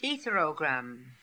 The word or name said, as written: etherogram